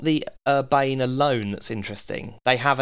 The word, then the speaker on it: Urbane: Or Urbani, I've heard it pronounced once. – Oh, really? I'd love to know by whom. But I think it was ironically. Yeah. I hope it was ironically on a podcast last week.